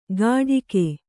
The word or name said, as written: ಗಾಢಿಕೆ